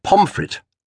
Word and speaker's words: Pontefract: Our first glimpse of Edward Henry Eustace Pontefract, but pronounced Pomfret,